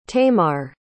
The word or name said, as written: tamar